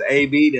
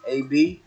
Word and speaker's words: AB: Yeah, so again, my name is AB. That's spelled A period B, AB. You can find me at Abnormal Music. That's A-B-N-R-M-L.